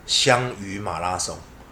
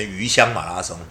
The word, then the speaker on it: 香鱼马拉松: 那我就说，那我在二零一六年我就说，那我为什么不办一个叫做香鱼马拉松？啊，当年还有很多人念成鱼香马拉松，就就是我我莫名其妙，不重要。